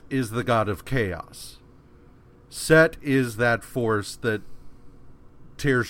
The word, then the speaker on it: Seth: Which he blasts Set. It's spelled Seth, so I'm just like, in my notes, I'm fucking up. He blasts Set, which causes Set to lose a hand, and then, like, the ship crashes, and all of the dead soldiers are dead now.